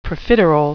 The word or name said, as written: profiterole